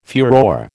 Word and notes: furore: American English

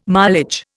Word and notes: mileage: Indian

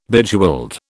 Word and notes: Bejeweled: British English

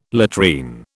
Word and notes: latrine: British English

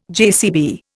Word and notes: JCB: Indian

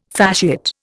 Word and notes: fasciate: Indian

